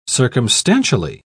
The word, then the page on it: circumstantially: British English